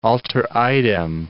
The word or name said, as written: alter idem